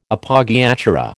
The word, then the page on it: Appoggiatura: American English